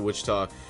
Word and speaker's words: Wichita: Yeah, you said it right, close to Wichita.